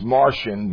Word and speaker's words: Marcion: And so it's pronounced Martian, but people think it, we think it comes from Mars, so but it's not spelled that way.